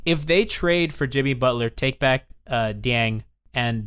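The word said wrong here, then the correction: Dieng: they keep, I can never say his name, Gorgie Dang? – Jang.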